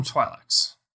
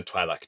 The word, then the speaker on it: Twi'lek: And the follow-up question is, how do you pronounce the name of the species of tailheads from Ryloth? – I call them Twi'leks. – I go back and forth, but today's a Twi'lek day. Sometimes Twi'lek. I think I picked up Twi'lek from the Phantom Menace game and then just ran with it.